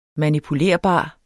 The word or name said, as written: manipulerbar